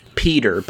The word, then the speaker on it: Piotr: Peter, but it's spelled P-I-O-T-R.